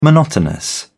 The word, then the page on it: monotonous: British English